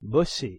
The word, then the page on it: bosser: French